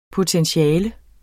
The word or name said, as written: POTENTIALER